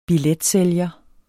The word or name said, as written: billetsælger